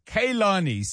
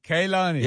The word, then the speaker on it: Kehlani: and the pronunciation guidance is Kehlani. So help me. – That's the one. – Kehlani.